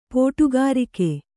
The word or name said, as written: ಪೋಟುಗಾರಿಕೆ